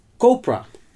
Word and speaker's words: Copra: En dat is Copra. Zo spreek je dat ook uit.